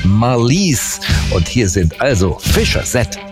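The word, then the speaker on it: Fischer-Z: Ganz einfach, die Band war sehr lustig drauf und hat sich gedacht, wir nehmen einen Namen, der so ähnlich wie Fish's Head klingt. Ausgesprochen Fisher's Head, ja, also Fischkopf.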